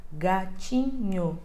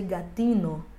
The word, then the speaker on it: gatinho: Vou utilizar CHI para fazer o som de TI e o N, que é aquele Nzinho com o tio em cima, e o O para fazer o NHO, gatinho. Se eu ensino para ele, escrita exatamente como é em português, com GATINHO, a pronúncia dele vai sair gatino.